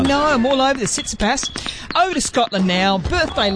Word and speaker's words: Tsitsipas: And while we're at it, talking about gentlemen tennis players, to Greece, happy 28th birthday to Stefanos Tsitsipas.